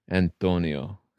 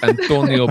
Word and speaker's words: Antonio: So to me, what would make this a classic is Antonio. And you have to say it like that. Antonio Banderas.